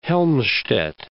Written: Helmstedt

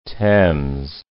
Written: Thames

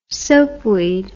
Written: soapweed

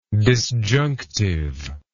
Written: disjunctively

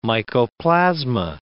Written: mycoplasma

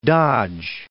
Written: Dodge City

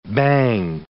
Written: bhang

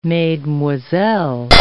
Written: mesdemoiselles

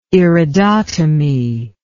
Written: iridotomy

